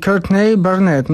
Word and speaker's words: Courtney Barnett: Nie to, że jestem jakaś noga językowa, ale długo uczyłem się wymawiać poprawnie jej imię i dopiero w Sydney, w sklepie muzycznym, pewien sprzedawca powiedział mi Courtney Barnett. No i tego się trzymajmy, tak zostaje.